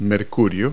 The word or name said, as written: mercury